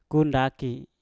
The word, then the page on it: ku ndaki: Mpur